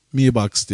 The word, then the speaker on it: Mi Box: Mi Box diye okunuyor.